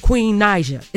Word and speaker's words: Queen Naija: Queen Naija. Am I saying it right? – Yes, you are.